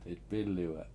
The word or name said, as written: 7itp$Iliwe7